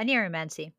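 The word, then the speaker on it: oneiromancy: And I think that kind of goes with most forms of divination, because I think isn't dreaming like onryo, something oniriomancy? – Yeah, oniriomancy. Mm-hmm. – Did I say it wrong? How do you say it? No, I think you said it right.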